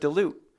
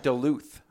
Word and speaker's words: Duluth: All this time I've been calling it Duluth. Because in Georgia, it's Duluth, Georgia, not Duluth. – No, yep. We pronounce Duluth all here. The letters.